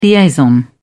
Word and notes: liaison: British English

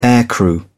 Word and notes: aircrew: British English

